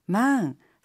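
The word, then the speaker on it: マン: 하이 망, 응, 남성을 뜻하는 영어 맨의 일본어식 발음인데요. 모음 차이가 있으니 주의해 주세요.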